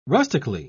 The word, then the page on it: rustically: British English